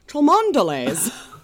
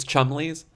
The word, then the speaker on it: Cholmondeleys: Is that Chumley is spelled C-H-O-L-M-O-N-D-E-L-E-Y-S. So if I were to just try to pronounce it, I would say Chumondeles. – And they'd say, excuse me, it's Chumleys.